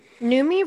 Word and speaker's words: Noomi: Noomi Rapace, I feel like, played her. Her name rhymes with Rooney.